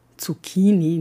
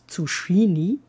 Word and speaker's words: Zucchini: Abgesehen von der Aussprache, Zucchini, nicht Zucchini, wie nennt man ein einzelnes Exemplar dieses Gemüses?